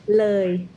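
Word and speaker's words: Loei: Say Loi for me, please. – Loi. – There you go. That's how you say it.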